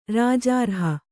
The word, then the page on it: ರಾಜಾರ್ಹ: rājārha